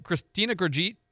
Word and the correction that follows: Kristalina Georgieva: What is it? Oh, Kristalina Georgieva.